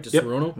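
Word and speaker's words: Disaronno: He now works as the Canadian brand ambassador for Di Serono. Did I say that right? Di Serono? – Yep.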